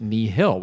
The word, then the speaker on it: nihil: and you pronounce it Nihil, which is very helpful.